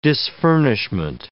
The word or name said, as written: disfurnishment